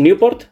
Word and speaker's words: Newport: Newport. ¿Lo he pronunciado bien, chicos? ¿Newport? Perfecto.